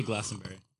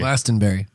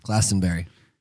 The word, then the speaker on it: Glastonbury: Excuse me, how do you pronounce Glastonbury? – Is it Glaston or Glastonbury? – Glastonbury. – Glaston. – Glastonbury.